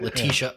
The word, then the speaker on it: Letitia: Letitia.